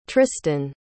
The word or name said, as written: trystan